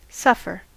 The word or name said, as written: suffer